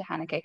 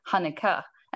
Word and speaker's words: Haneke: Oh, actually, which pronunciation of Haneke are we going with? Because I was just watching an interview with him, and it's like Haneke, and I was like, I don't think how you say it. – Anglo Haneke, or we can go the sophisticated Euro Haneke.